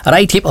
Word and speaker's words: Raitip: ไรทิป